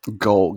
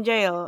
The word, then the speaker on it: Gaol: but I can't remember now how Carlos had pronounced jail. – Gaol, get gaol, or gaol, yeah, gaol. something like that, because it's spelled G-A-O-L. – L-L, yeah. – And so, yeah, so he said it something like that, and the taxi driver was like, oh, like, Kilmainham jail, and obviously they say it correctly.